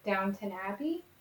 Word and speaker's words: Downton Abbey: But when I was looking through his filmography, I guess he is most notably known for Downton Abbey, which I hope I said that right.